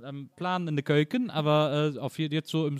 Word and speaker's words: Keuken: Also in the Keuken sind wir noch, sorry, wie spricht man, Nils, du weißt es besser, wie spricht man es richtig aus? – Keuken, in the Keuken. Ja. Keuken, ja. Oh, das macht man ja gerne, ne?